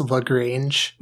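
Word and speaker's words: La Grange: Then Baba O'Reilly by The Who, and then the final one at 3% is Lagrange by ZZ Top. So these are the options we have before us. – I believe it's pronounced Lagrange.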